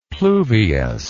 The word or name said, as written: pluvious